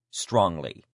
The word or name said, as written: strongly